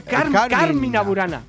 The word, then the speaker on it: Carmina Burana: No, es Carmina Burana. Porque y que no es un nombre de una piba, son unos cánticos que había. – Es porque nos comemos medievales. – El acento en la A, ¿verdad? – Nos lo comemos todo, compadre.